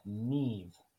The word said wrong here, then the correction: Maeve: You got your consonant pronunciations correct. It's those darn Irish vowels, though. Maeve.